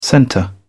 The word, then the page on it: centre: British English